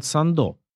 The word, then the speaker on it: Сандо: Так вот, эту компанию в 1886 году основали два человека, объехавший полсвета авантюрист и негоциант Эдуард Сандо. Именно так, с ударением на последнем слоге, произносилась его фамилия, а не, как говорят в России и Африке, с ударением на первом и акцентируя звонкое «з».